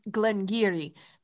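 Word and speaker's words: Glen Garioch: But, yeah, it's spelled, but it's pronounced Glengarry because of the Doric, which is not just Scotch Gaelic, but it is a very specific localized dialect.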